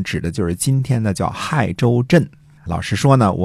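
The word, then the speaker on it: 解州: 最后呢，我弄明白了，这个字儿呢既不读“ 解”，也不读“ 谢”，而是读成“ 亥”。亥州镇的这个地理位置呢，在今天山西运城的亥池西边儿啊。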